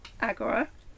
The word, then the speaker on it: Agora: Now, Agora, Agora, is that how you pronounce it? – Yeah. – Agora is how it's supposed to be pronounced. – In ancient Greek, right? – In ancient Greek.